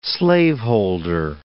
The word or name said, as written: slaveholder